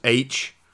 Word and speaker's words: Aech: But it's spelled out in the book A-E-C-H. Like, it's not pronounced... I guess it is pronounced H, but I was thinking it was just the letter H. – Oh, right. Yeah. – And it's not. It's a whole different character's name.